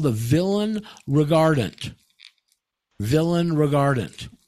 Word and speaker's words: villein: Go to Black's Law Dictionary, and you look it up, and it's going to be spelled V-I-L-L-E-I-N, villain. We say villain. It's spelled villain.